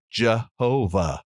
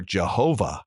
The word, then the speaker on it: Jehovah: We have to put in the A and the E vowels to be able to pronounce it. But others have put in an E and an O and an A in the word, leading it to be pronounced Yehovah, or finally, Jehovah. So this important name of God, which means I am, can be pronounced Yahweh or Jehovah.